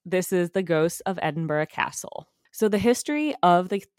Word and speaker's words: Edinburgh: The most common I heard was Edinburgh. So that's how I'm going to be pronouncing it.